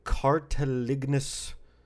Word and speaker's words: cartilaginous: They have cartilaginous structures.